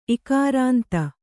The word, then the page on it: ಇಕಾರಾಂತ: Kannada